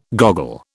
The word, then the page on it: goggle: British English